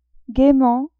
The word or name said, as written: gaiement